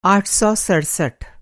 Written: आठ सौ सड़सठ

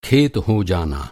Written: खेत

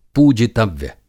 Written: पूजितव्य

